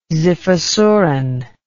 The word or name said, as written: xiphosuran